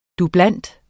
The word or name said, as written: dubleant